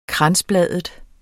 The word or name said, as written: kransbladet